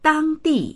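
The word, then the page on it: dang1di4: Mandarin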